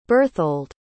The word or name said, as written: Berthold